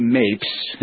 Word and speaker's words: Mapes: I have one here, and this is from Mary Mapes.